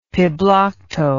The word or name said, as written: piblokto